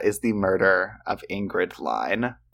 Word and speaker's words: Lyne: It's Ingrid Line. I think I said it correct at the beginning, but it's spelled L-Y-N-E, and I've been saying Lynn the past half.